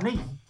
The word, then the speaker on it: Neath: did I say that right? Neath? – Yeah, that's right, Neath.